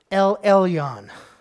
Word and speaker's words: El Elyon: Most High. El Elyon. That's how you say it in the Hebrew, which was first used in Genesis 14 by Melchizedek, who gave this name for God, the Most High.